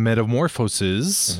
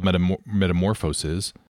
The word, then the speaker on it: Metamorphoses: In Metamorphoses, mm-hmm, by Ovid, there is a story. – Did you say Metamorphoses by Ovid? – Yes, well, it's spelled metamorphosis. – But metamorphosis.